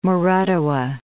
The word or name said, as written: Moratuwa